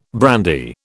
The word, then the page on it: brandy: British English